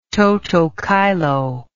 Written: toto caelo

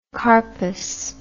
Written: carpous

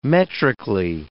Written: metrically

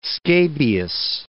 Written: scabious

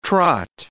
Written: trot

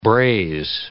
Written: braise

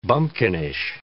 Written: bumpkinish

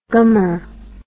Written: gummer

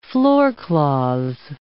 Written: floorcloths

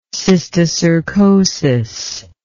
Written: cysticercosis

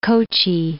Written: Kochi

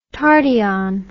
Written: tardyon

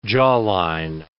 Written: jawline

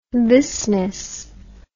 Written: thisness

